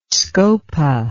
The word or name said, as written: scopa